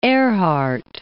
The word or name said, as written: Erhard